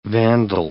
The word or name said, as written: vandal